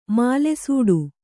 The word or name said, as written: ಮಾಲೆಸೂಡು